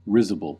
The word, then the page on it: risible: American English